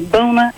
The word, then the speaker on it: Boona: Sir, my name is Bona, and I'm calling from Dallas, Texas. – How do you spell that, Bona? – B-O-O-N-A.